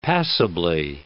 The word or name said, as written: passably